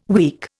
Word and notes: week: Indian

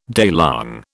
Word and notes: daylong: British English

